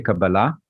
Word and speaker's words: Kabbalah: But in Hebrew these days, you would say Kabbalah, which emphasis would be at the end. But in America, I've noticed people don't understand when I'm saying Kabbalah.